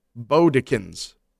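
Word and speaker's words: Bodkins: It's pronounced Bodekins, like when you tie a bow.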